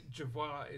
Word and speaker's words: Jervois: So, Jervois is the way Jervois. We pronounce it.